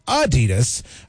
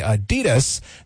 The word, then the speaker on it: Adidas: And finally, Americans pronounce Adidas wrong. It's supposed to be Adidas with a slight emphasis on the first syllable, not the Adidas that we tend to say it.